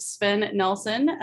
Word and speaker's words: Sven Nelson: I am, as I said, super excited to have Sven Nelson with C2C Resources.